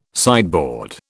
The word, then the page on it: sideboard: British English